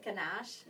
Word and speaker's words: ganache: Is it ganache? – Mm. You did say it right.